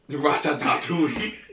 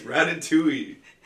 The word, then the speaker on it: Ratatouille: Ratatouille. – It's Ratatouille. – No. – Yep. I promise you it's Ratatouille. I wish you were making this up for comedic purposes. Ratatouille. No, it's Ratatouille.